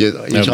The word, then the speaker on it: Erdoğan: Hát nagyon kedves vendég a török Erdoğan, különbözőképpen ejtik a nevét, Erdogánnak írják, de gondolom Erdogan Hallottam Erdogan. a BBC, így, így Erdogan, hallottam.